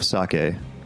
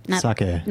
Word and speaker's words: sake: Like, Ida, it's not sake. It's sake. As opposed to sake, which is salmon in Japanese.